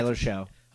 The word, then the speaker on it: Shough: Tyler Shough.